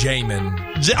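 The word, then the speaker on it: Xiamen: It's Xiaomin Marathon. I think is how you say okay. it. Okay. Say it again. Xiaomin. Jame, it, it's, it's Jamin. – Oh, Jamin China. He knows.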